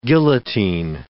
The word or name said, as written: guillotine